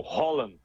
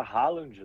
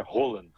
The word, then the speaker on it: Haaland: O Holland. – Haaland, né? – O pessoal no Brasil fala Haaland, né? – O Haaland. – Mas aqui o norueguês é Holland, Haaland.